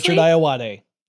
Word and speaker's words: Richard Ayoade: Did I say that correctly? – Richard Ayoade. Yeah.